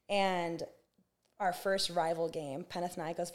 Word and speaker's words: Panathinaikos: Panathinaikos was how you pronounce it.